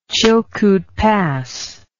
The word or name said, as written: Chilkoot Pass